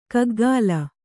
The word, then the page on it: ಕಗ್ಗಾಲ: kaggāla